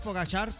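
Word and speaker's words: Pogačar: ¿Es tendencia Pogacar o Pogachar? ¿Cómo se dice, güey? – Como quiera, como decís vos, Pogachar, pues como se pronuncia o lo pronuncian los deportivos normalmente, porque ganó pues hoy la etapa del Tour de Francia el día de hoy.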